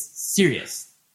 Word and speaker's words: Ceres: You say the band name Sirius? – Dexy's Midnight Runner. – Yes. No. It's spelled C-E. No, it's like C-E-R-E-S. But it's Sirius.